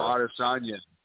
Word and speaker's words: Adesanya: Adesanya and Alex. – Israel Adesanya.